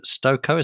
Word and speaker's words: Stokoe: This is from Professor Elizabeth Stokoe. Is that how you say her name? – Yeah, that's right.